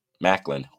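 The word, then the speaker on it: Maclin: But TNA Sacrifice, it was Nick Nemeth and Steve McLean. Did I say that right? Macklin. Ooh, I messed his last name up.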